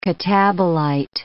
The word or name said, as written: catabolite